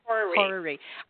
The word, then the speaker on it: horary: But I would love to know, if you want to do the, how do you say it again? The horary, is that right? – It's like horoscope. – Horary.